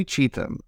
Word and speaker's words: Cheetham: I'm sorry if I'm not pronouncing this correctly. Is that correct, Julie? – It's Cheetham. Close enough. – Cheetham. Okay, well, let's use that. Julie Cheetham.